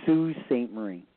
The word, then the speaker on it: Sault Ste. Marie: It's Sault Ste. Marie. – Sault Ste. Marie, but it's how's Sault, that first word spelled though? – S-A-U-L-T. It's French though, right? So it's pronounced differently, and it's a Canadian.